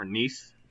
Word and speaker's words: Nice: A slang term, well, not necessarily a slang term, but a term that was named after the Mediterranean climate of Nice, France, or Nice, France, I believe it's pronounced.